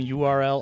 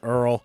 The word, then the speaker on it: URL: Actually, I've been calling him URL all this time, but it's pronounced Earl, and that tune was called Honey.